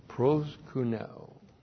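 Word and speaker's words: pros kuneo (προσκυνέω): Proskuneo. P-R-O-S, that you recognize that, that's the preposition pros. So we have a compound word, pros plus the verb kuneo, K-U-N-E long O, pros kuneo.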